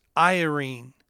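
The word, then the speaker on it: Irene (εἰρήνη): You can study the original Greek. It's pronounced Irene.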